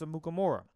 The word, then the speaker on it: Amukamara: Prince Amukamara. Good pronunciation on that.